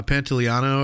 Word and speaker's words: Pantoliano: Oh, and then uh, Joe Pantolino. Lino? – Yeah, uh, Pantoliano, or I think I think yeah, that's how you say yeah, it. but, said it right.